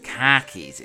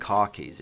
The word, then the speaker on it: khakis: Khakis, it's khakis, it's not car keys.